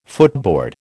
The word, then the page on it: footboard: American English